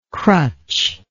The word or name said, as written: crutchlike